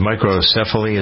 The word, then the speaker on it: microcephaly: If you've got a baby, I think the first baby in Texas with Zika microcephaly, I never can pronounce it, microcephaly, is that how you pronounce it? – Yeah, that's it.